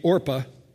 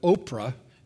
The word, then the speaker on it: Orpah: It's going to be hard for some of us because people couldn't pronounce the name, and so they began to call her Oprah instead of Orpah, which is what the birth certificate originally said. So every time I say Orpah, I think I'm mispronouncing the name. But it's Oprah's name who's wrong, not this woman's name who's wrong.